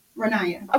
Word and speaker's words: Ronaya: Ronaya.